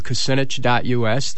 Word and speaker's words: Kucinich: That name is spelled K-U-C-I-N-I-C-H. Kucinich. Now, I know that some people have trouble pronouncing it. I want to reassure you that it took me years to learn how to pronounce it.